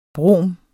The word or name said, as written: brom